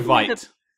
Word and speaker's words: Veidt: Or, by the way, are we saying Veidt or Veidt? – I always go Veidt, but I was going to lean on what you were going to pronounce, so it's up in the air. – I was going to ask both of you, so yeah, that's fine. Well, let's go Veidt. – That's the proper German pronunciation, so let's go with that.